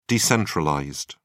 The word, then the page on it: decentralized: British English